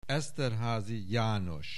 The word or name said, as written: Esterházy, János